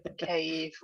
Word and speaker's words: Kiev: Kiev. Yeah. – So think of it as apparently giving special K to Eve.